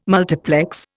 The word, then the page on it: multiplex: Indian